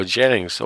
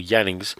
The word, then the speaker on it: Jannings: And that, of course, was Emil Jannings, or Jannings, depending on your pronunciation.